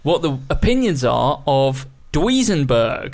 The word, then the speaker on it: Duesenberg: I just didn't know how you pronounce it. Duesenberg. Duesenberg. Right.